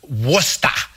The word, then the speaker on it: Worcester: It's pronounced Worcester.